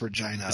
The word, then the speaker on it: Regina: There was a kid, they had a picture of him on Deadspin who had a sign, because it's Saskatchewan's playing out of Regina or Regina. – I think it's pronounced Regina.